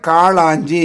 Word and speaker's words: காளாஞ்சி: இவருடைய இது வந்து காலாஞ்சி ஐயா. – வாழ்த்துக்கள், வாழ்த்துக்கள் வாழ்த்துக்கள். காளாஞ்சி என்று கூறுவது சரவணா, அதுக்கு பெயர் காளாஞ்சி. ஞாபகம் இருக்கும் எங்கள் நாகப்பருக்கு என்ன கௌரி வந்து முன்பு இதை இருக்க சொல்லியிருந்தார் இல்லையா ஒரு தடவை பொக்கிஷத்தில்.